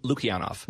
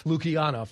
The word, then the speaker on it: Lukianoff: Lukianoff. – L- Lukianoff. – Lukianoff. Thank you very much. I missed somewhat of a syllable there.